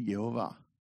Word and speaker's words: Yehovah: And he said that all the manuscripts have vowels for Yud Hey Vav Hey, and is pronounced in Hebrew Yehovah.